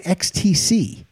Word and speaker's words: XTC: Is it pronounced Ecstasy? Or is it XTC? – I think it's XTC. But I could be very wrong because I think it is XTC. – I think it's XTC too, but it's also ecstasy.